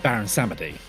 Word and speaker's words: Baron Samedi: The last we see of Baron Samedi, and yes, this is how they pronounce it in the film at least.